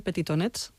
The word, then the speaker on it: Petitonets: que se llama, si no lo digo mal, Petitonets. – Lo dices perfecto.